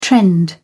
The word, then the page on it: trend: American English